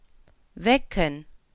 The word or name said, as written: wecken